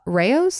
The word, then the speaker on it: Rao's: Rao's? Is that how you say it? Yeah.